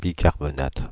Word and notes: bicarbonate: France (Île-de-France); /bi.kaʁ.bɔ.nat/

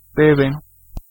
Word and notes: deben: /ˈdeben/

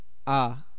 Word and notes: a: /eɪ/; /ə/

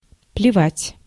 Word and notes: плевать: /plʲɪˈvatʲ/; plevat

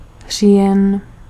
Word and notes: oktober: /ʊkˈtuːbɛr/; /ʊkˈtʊbɛr/

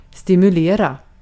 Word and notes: stimulate: American English

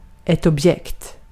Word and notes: esine: /ˈe̞sine̞ˣ/

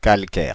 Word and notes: calcaire: [kal.kɛʁ]; Paris

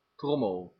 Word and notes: various: American English; /ˈvɛəɹiəs/; /ˈvɛɹiəs/

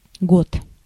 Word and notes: año: /ˈa.ɲo/

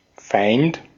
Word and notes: Feind: /faɪ̯nt/; /faɪnt/

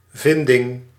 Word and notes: découverte: France; [de.ku.vɛʁt]

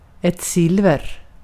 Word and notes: argent: /ˈɑɹ.dʒɛnt/; /ˈɑː.dʒɛnt/

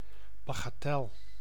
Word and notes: babiole: /ba.bjɔl/

